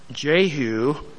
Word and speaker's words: Jehu: Jehu, as it should read, the J being pronounced as a Y in Hebrew, Jehu was the closest.